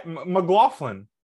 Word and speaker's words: McLaughlin: So you just said it wrong again. – Did I say it wrong again? – Yeah. – McLaughlin. – It's McLaughlin.